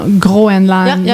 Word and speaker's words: Groenland: That's the French way of saying Greenland, right? – No, Groenland. – Yeah. – In English, like the country is exactly Greenland in English, but in French it's Groenland.